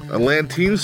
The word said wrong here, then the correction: Atlantean: sword from Conan Barbarian. I'm pronouncing that wrong. Atlantean sword.